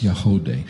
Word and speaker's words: Yagody: It's a spectacular performance, Y-A-G-O-D-Y, but it's pronounced Yahodeh.